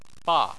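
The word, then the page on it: ba: tone 4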